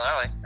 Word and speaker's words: Lally: Yep, Sean Lally.